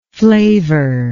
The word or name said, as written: flavour